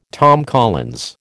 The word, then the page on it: Tom Collins: American English